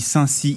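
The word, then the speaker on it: Saint-Sixt: Est-ce que c'est comme ça qu'on prononce le nom de votre commune ? Parce que ça s'écrit Sixte, mais en général ici on dit Cy. – Apparemment on dit Saint-Cy ici, mais il y a quand même...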